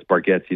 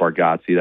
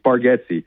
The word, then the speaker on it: Bargatze: I'm a huge Nate Bargatze fan, and real fans know it's Bargatze, not Bargatze.